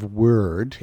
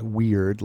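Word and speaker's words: Wyrd: Web of Word. It's W-Y-R-D. Sort of, it's Scandinavian. It's pronounced word, not weird like you might think.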